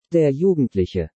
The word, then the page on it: Der Jugendliche: German